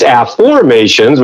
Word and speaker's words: Afformations: It's not the old affirmations that you've heard a million times. It's Afformations, which is spelled Afformations, Afformations.